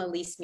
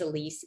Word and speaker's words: Elise: It's just my name, Elise Museles.